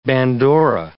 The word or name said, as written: bandora